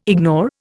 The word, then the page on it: ignore: Indian